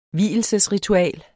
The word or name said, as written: vielsesritual